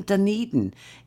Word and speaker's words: Dunedin: And she's in Dunedin in New Zealand. – New Zealand? – I don't know whether that's pronounced properly. And she said... – Yes, it is.